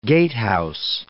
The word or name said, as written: gatehouse